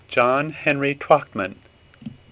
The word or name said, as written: Twachtma